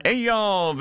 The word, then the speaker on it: Eyal: Eyal Vilner.